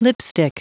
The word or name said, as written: lipstick